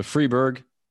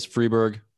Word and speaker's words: Freiburg: So Freiburg, people pronounce it all kinds of different ways. Freiburg, Freiburg, I guess that depends on whether you prefer to speak French or German.